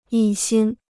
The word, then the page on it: 一心: yī xīn